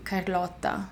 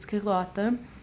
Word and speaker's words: Carlotta: In Italian, it's technically Carlotta, and in Portuguese, it's Carlota. And people hear you just say Carlotta,